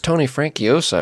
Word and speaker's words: Tony Franciosa: It also stars Tony Vernalisla. – Yeah. Whoa, I don't think that's right. – Probably not, but you know how I pronounce things. Tony Francuasa. – No, my frere. It's Tony Franciosa, I'm pretty sure, right? – Probably.